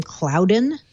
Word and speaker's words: Cloudyn: This company, I think they pronounce their name Cloudyn, C-L-O-U-D-Y-N, Cloudyn.